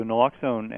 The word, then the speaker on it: naloxone: Yeah, so naloxone, and you pronounced it correctly, is a miraculous anecdote, really.